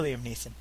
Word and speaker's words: Liam Neeson: Poor Liam Neeson.